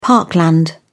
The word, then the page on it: parkland: British English